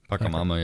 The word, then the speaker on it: Pachamama: Specifically, the movement is promoting a neo-paganism and is invoking Pachamama, the Incan earth goddess. I don't know if you ever heard of... – I have, yeah. – Okay. I don't know if I pronounced that. – Pachamama, yep.